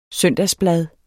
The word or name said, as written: blad